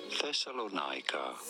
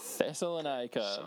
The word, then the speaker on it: Thessalonica: How do you go about pronouncing it? Thessalonica. – Thessalonica. That is the place name, Thessalonica. So anyone who says it any differently, they're wrong.